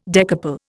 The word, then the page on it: decouple: Indian